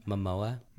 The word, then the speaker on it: Momoa: Momoa. – Momoa? Almost like mimosa? – It's M-O-M-O-A.